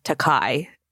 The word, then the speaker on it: Takei: It is pronounced Takei, but Bill has always said Takei.